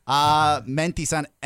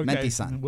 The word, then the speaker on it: Mentisan: Menti sun. – Spell it. – Mentisan, MENTISAN. – Nice, okay. – Mentisan. – We'll need that. – And it sounds like you're getting in Japan. Mentisan.